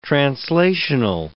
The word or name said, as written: translational